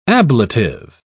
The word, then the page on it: ablative: British English